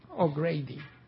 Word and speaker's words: O'Grady: O se pronuncia Grady. – O'Grady.